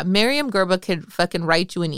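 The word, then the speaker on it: Myriam Gurba: Miriam Gerba. And the last name is spelled G-U-R-B-A. First name M-Y-R-I-A-M. Miriam, common spelling, Gerba, G-U-R-B-A.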